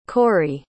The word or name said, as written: corri